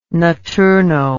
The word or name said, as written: notturno